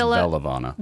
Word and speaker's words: Veluvana: Am I saying it right? – I think it's Velavana. – Velavana? – V-E-L-U-V-A-N-A.